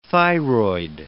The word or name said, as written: thyroid